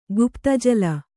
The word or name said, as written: ಗುಪ್ತಜಲ